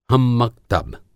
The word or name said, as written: हम-मक्तब